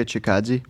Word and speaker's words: Chikadze: There's a good fight with here you can see Calvin Kattar and Giga Chikadze. – How do you say his last name? – Giga Chikadze.